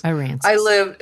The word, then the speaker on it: Aransas: And you live not very far from that, Port Aransas. Or is that how you say it? – I lived at Port Aransas. – Aransas.